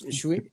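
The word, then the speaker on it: Świątek: What the hell do you say her name? It's Swiatek. Swiatek. – We're told to throw an N in there, like Swiatek.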